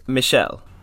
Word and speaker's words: Michel: Is it Michael or is it Michael? – It's Michel. – Michelle, I apologize to Mr. Gondry, but Michel Gondry.